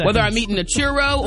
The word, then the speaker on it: churro: Have a churro.